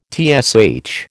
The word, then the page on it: TSH: American English